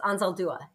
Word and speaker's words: Anzaldúa: So you mentioned Gloria Anzaldúa. Am I saying that correctly? – It's a hard one. It's Anzaldúa.